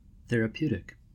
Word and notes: therapeutic: American English

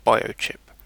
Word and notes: biochip: British English

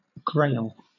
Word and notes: grail: British English

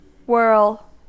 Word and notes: whirl: American English

